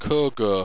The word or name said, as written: cogoa